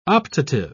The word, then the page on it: optative: British English